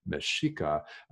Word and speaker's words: Mexica: And by the way, Mexica is spelled just the same way as Mexico, the country, but with an A at the end of the word instead of an O. Mexica, uh, that's the name of the healing tradition.